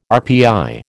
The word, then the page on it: RPI: American English